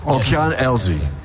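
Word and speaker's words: Океан Ельзи: How do you say that? – Okean Elze.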